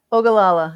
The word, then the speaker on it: Ogallala: But the Ogallala, is that right? – Ogallala, yeah.